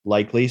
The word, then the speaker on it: Likely: It's spelled L-I-K-E-L-E, but I'm told it's pronounced Likely, so I'm going with that.